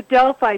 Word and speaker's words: Delphi: If they hold a town council, and it's called, forgive my pronunciation, the Delphi technique. Is that right? – Yeah, talk about that. What is he talking about? – Yes, it's the Delphi technique developed by the RAND Corporation in the 1950s.